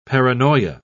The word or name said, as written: paranoia